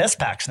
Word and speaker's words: SPAC: Let's just make it SPACs now. We can change it. – Why don't we make it like SPAC, like Tupac? – Yeah.